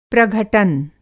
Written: प्रघटन

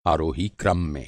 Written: आरोही

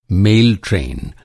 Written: ट्रेन

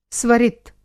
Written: स्वरित

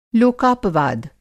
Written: लोकापवाद

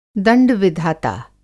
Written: दंड-विधाता

